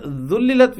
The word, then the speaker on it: ذللت: Now this word ذللت with ذال, and ذال is thin letter.